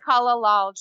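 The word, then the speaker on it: Kalalau: It's pronounced Kalalal Trail. K-A-L-A-L-A-U, I think is right.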